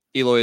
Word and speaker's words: Eloy: Eloy is a White Sox. That's how you say it.